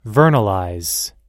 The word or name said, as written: vernalize